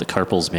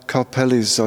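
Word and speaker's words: Karpeles: I realize I've been saying that wrong for like my entire life. I've been calling it the Karpel's Manuscript Museum. – Like Karpel Tunnel. Yeah. Karpeli's, I believe, Central European.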